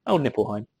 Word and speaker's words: Nibelheim: Yeah, well, I mean, pretty much once I get to Nibelheim, or however that's pronounced. – Nibelheim.